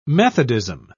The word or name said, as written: methodism